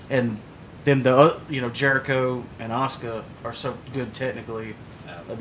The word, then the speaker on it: Asuka: Asuka. – Asuka. – Yeah. – It's spelled like Asuka. – Yeah. But it's, and Asuka. It's like Shinsuke. It's Shinsuke.